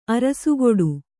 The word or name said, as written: ಅರಸುಗೊಡು